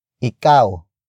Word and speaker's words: ikaw: Ikaw lang ba ang kakain.